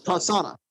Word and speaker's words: Prasanna: So it's like Pras, P-R-A-S, and then yeah. Anna, the name Anna afterwards. It's pronounced so, Persona.